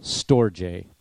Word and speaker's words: storge: Then storge, everybody say the word storge. That's how it's pronounced.